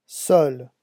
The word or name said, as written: seul